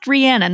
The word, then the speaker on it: Rihanna: It's pronounced Rihanna, not Rihanna.